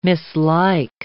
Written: mislike

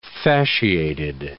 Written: fasciated

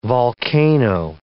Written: volcano

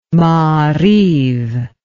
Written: maariv

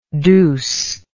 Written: douceness

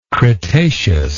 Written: cretaceously

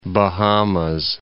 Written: Bahamas

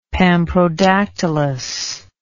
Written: pamprodactylous

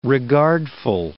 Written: regardful

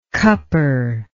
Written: cupper

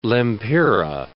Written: lempira

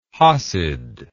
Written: hassid

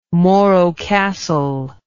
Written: morro castle

